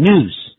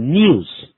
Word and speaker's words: news: The good news, 一般美式英语念成 news, 英式英语念成 news。